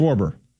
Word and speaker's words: Schwarber: Schwarber.